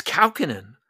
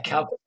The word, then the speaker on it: Kaukonen: Ha, he, it's a Finnish name. Hmm. So he's Finnish. Uh, partly, yeah. How do you say it? Kalkinen. It's Kalkinen. – Yeah, Kalkinen.